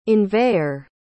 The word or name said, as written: inveigher